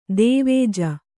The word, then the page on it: ಧ್ವಜ: Kannada